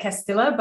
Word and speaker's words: Castilla: Well, I actually say Castilla, but I just thought I'd give you props for knowing how it should be pronounced.